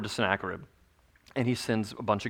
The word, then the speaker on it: Sennacherib: And King Sennacherib, I looked that up, I had to figure out how to pronounce that, King Sennacherib, and Jack, he checked me, so Sennacherib.